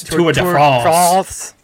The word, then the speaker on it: Tour de France: You didn't say it right. It's Tour de France.